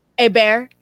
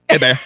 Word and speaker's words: Hebert: Was that Ben Hebert? – Yes. It is. Well, it's Ben. No. A bear. – A bear. – A bear. Sorry. My mistake. – I never know how to say his last name.